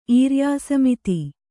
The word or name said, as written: ಈರ್ಯಾಸಮಿತಿ